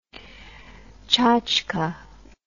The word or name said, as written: chatchka